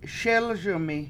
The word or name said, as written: SElS1mi